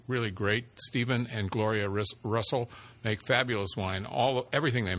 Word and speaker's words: Reustle: This is from Russell, which is spelled R-E-U-S-T-L-E.